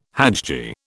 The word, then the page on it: hajji: British English